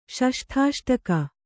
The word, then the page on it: ಷಷ್ಠಾಷ್ಟಕ: ṣaṣṭhāṣṭaka